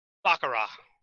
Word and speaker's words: Baccarat: Baccarat.